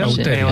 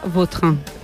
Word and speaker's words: Vautrin: Bauterín. Sí, elegante. – La original francesa, Vautrin.